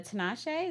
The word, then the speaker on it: Tinashe: I've been listening to Tinashe.